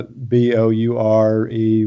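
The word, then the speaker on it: bourré: Uh, B O U R E with the accent. – Oh, it's after it's after the Cajun card game. That's in Louisiana. That's pronounced boure, but I, okay.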